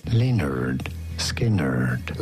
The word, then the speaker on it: Lynyrd Skynyrd: su come si pronuncia esattamente il nome del gruppo, che è scritto Lynyrd Skynyrd, sì, con tutte Y, e la pronuncia corretta è Lynyrd Skynyrd.